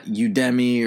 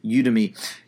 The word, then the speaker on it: Udemy: And I remember they one time made a whole video about how to pronounce the name, not Udemy or all these other variations, but it's Udemy.